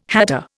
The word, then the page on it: hatter: Indian